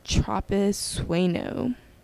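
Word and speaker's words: Tropisueño: So the next place is called Tropis Bueno. It's spelled as TROPISUENO.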